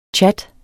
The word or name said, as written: tjat